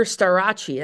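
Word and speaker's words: Starocci: there's another guy named Carter Starace, that's the Italian pronunciation, from Penn State.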